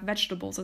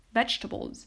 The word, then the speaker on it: vegetables: Moving on, we have vegetables. And even though this word has four syllables, most often, 9 times out of 10, it's only pronounced with 3 syllables.